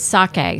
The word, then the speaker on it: sake: It's sake.